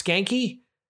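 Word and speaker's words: Schanke: We've got Skanky, it's spelled S-C-H-A-N-K-E. I just want to point that out. It's pronounced Skanky.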